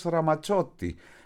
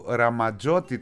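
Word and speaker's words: Ramazzotti: Και μάλιστα βλέπω εδώ στις ανακατεμένες, είναι η αλήθεια, σημειώσεις μου, ότι σήμερα έχει γενέθλια ο Έρος Ραματσότη, που Ραματζότη τον λέγαμε, Ραμαζότη τον λέγαμε, έχει αυτό το διπλό Z, αλλά στα ιταλικά αυτό νομίζω προφέρεται ταφ σίγμα, δηλαδή το πίτσα, και αυτό με δύο Z είναι, και ποιο άλλο, θα σκεφτώ και άλλες λέξεις.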